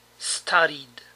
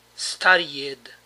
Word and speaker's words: studied: Studied. Como ven, la ed se pronuncia d solamente. No es studied, sino studied.